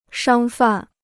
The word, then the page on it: 商贩: shāng fàn